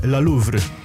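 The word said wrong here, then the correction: La Louvière: La Louvier, przepraszam.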